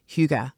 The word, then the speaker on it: hygge: They burn candles, they eat good food, they invite people into their cozy homes, and they embrace hygge.